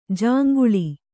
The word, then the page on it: ಜೊಂಗುಳಿ: joŋguḷi